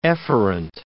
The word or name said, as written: efferent